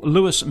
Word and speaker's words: Louis: Is it pronounced Lewis, Paul? – Yes, Lewis. – Paul Lewis Metzger.